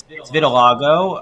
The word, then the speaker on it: vitiligo: He did have vitiligo. Is that how you say it? – I didn't say it because I don't know how to say it. – It's vitiligo. I mean, it's a.